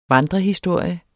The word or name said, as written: vandrehistorie